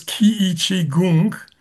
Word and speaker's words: Qiichi Gong: My Zen master name is Qiichi Gong. Qiichi is Chinese. Gong is similar to Qigong, but the Chinese pronunciation is Gong.